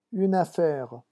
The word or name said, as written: une affaire